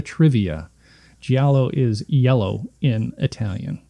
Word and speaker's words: giallo: They referred to it as a giallo. – It's giallo, yeah.